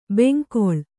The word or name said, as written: ಬೆಂಗೊಳು